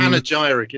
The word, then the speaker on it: Panegyric: It's available from Panegyric, it's pronounced.